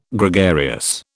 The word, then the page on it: Gregarious: British English